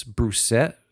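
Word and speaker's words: Brusatte: And he had on a guest, I think his last name is Broussette. – According to Google, that's how it's pronounced.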